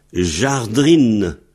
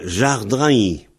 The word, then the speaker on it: jardin: Alors, au lieu de dire jardine, c'est devenu jardrine. Et alors, comme en S-20-E, beaucoup d'endroits, le in se prononce ain, jardrine devient jardrain. Jardrine, jardrain. Et il y en a qui disent jadrine, jadrain, jadrain. Donc, jardine, jardin, jadrine, jadrain. Jardine, jardine, etc. Mais ça s'écrit J-A-R-D-I-N.